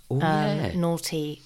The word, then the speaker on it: Noughty: I've been sent a bottle of white naughty AF wine. So naughty is spelt N-O-U-G-H-T-Y. Naughty as fuck.